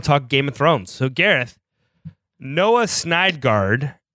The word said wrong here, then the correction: Syndergaard: Am I pronouncing that right? You're in New York. Am I pronouncing that right? – For a sports show, you're all over the MLB beat, bro. Syndergaard, man.